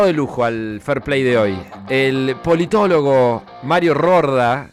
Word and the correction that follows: Riorda: Riorda. Eh, eh, Riorda, ¿pronuncié mal? Sí. ¿Qué dije? – Riorda. Rorda, dijiste. Rorda.